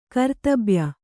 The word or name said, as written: ಕರ್ತಬ್ಯ